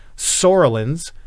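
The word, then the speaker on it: psoralens: Sorolins is the P, is silent P.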